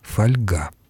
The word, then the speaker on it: фольга: Сегодня авторы новых словарей уже согласились с этим переносом и кодифицировали ударение фольга.